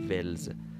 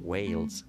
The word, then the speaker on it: Wales: تلفظ این ولز ولی آنچه آنان میگویند البته با تلفظ آمریکای شمالی یعنی کسایی که انگلیسی زبان هستن و اهل ایالات متحده و کانادا هستن این رو به این شکل تلفظ میکنن ویلز ویلز ویلز آنچه میتوان گفت ویلز وی ویلز آنچه ما به صورت جاری ادا میکنیم که غلط هست